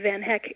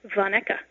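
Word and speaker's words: Van Hecke: In America, the E on the end of Van Heck is silent. In Belgium, the E is allowed to speak, so there the name is pronounced Van Ecke.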